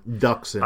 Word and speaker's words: Dxun: The thing that I remembered most about that book was that Onderon's moon, which I believe is pronounced Zun or Duxun, D-X-U-N. – Duxun, I believe.